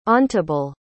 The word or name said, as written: ontibile